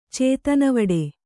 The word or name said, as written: ಚೇತನೆವಡೆ